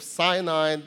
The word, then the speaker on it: Sin: Zin is spelled Z-I-N. It's slightly different from the second wilderness that we talked about, which is Sin, S-I-N.